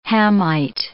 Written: Hamite

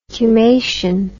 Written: cumacean

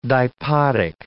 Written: dipodic

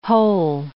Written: hole

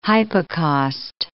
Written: hypocaust

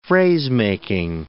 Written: phrasemaking

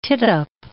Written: tittup

